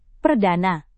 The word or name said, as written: perdana